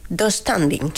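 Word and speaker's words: döstädning: Yo lo pronuncio... Dos standing. – Sí, así. Sí, tengo esa silla del Ikea, sí, se pronuncia así.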